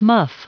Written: muff

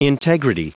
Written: integrity

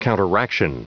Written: counteraction

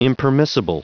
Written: impermissible

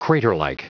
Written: craterlike

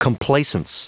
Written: complacence

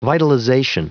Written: vitalization

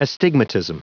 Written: astigmatism

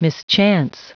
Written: mischance

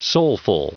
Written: soulful

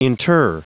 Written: inter

